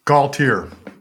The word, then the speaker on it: Gaultiere: Gaultiere.